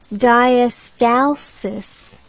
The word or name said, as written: diastalsis